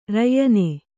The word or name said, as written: ರಯ್ಯನೆ